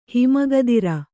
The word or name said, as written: ಹಿಮಗದಿರ